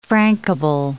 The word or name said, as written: frankable